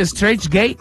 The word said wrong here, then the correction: Straight Edge: Straight Edge.